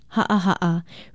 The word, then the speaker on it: ha'aha'a: Ha'aha'a is spelled with two okina or glottal stops, H-A-okina-A, and pronounced ha'aha'a.